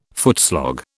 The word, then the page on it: footslog: British English